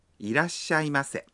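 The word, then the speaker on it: irasshaimase (いらっしゃいませ): Quand on entre dans un magasin ou un restaurant, les employés vous accueillent en disant irasshaimase ». Mais nous n'avons pas à répondre, n'est-ce pas Non. Sourire et s'incliner suffit. Lorsque vous prononcez irasshaimase », faites une légère pause après la.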